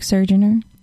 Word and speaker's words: Surgener: Follow me at Brooke Surgener. S-U-R-G-E-N-E-R. There's no T in it.